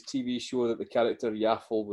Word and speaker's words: Yaffle: It's spelt like waffle, but with a Y at the start. But I feel like it would be pronounced yaffle as opposed to yoffle.